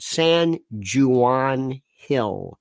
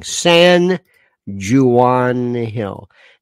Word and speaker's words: San Juan Hill: used to be called San Juan Hill, that particular area. That's the pronunciation. Incorrect, but nonetheless, that's a very big New York thing. San Juan Hill.